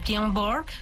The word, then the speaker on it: Björn Borg: En 1980, en mayo, Björn Borg ganó el gran slam de Roland Garros. No sé si así se pronuncia. – Sí.